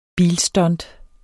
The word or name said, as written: bilstunt